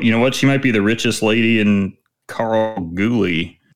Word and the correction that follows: Kalgoorlie: You were close. Whatever this you is. Got the last end of it right. – Kalgoorlie. – Don't overpronounce the R.